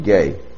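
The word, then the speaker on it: gay (γῆ): Now, of course, the Greek word here in earth is, as we've seen, the Greek word spelled G-E, pronounced gay.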